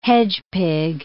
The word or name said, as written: hedgepig